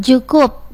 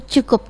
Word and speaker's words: Tjukup: Tulisannya TJUKUP Cukup. Tapi kan tegaknya dibacanya Cukup gitu ya.